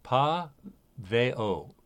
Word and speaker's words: Paweł: We would say his first name is Paweł. It's spelled P-A-V-E-L, but in Polish, Paweł.